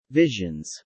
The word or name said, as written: visions